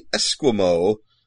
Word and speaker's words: Esquimaux: And the individual, when thus dressed, appears exactly like that of the Esquimo. I believe that would now, this is spelled E-S-Q-U-I-M-A-U-X.